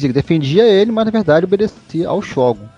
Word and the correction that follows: Shogun: Não fala Shogun, não existe Shogun. Sílaba tônica não, é Shogun.